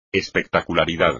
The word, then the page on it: espectacularidad: espagnol